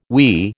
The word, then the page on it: we: American English